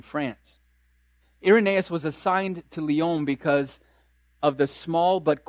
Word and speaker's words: Lyon: It's not pronounced lions. It's Lyon.